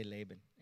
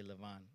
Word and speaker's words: Lavan: We say Laban. In Hebrew, they say Lavan. Like Jacob is actually Yaakov in Hebrew, or the way it's pronounced, not Jacob. We pronounce that in English.